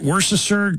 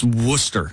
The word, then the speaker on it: Worcester: It was in, I don't know if this is the correct way to pronounce it, Worcestershire Country Club, but you know how they spell and pronounce it. I think it's Worcester. – Worcester, I think you're right. – Yeah, Worcester.